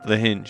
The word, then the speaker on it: Lahinch: Once we left Marianne on the West Coast, Lahinch? Is that how you say it? – Lahinch. – Lahinch.